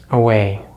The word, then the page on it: away: American English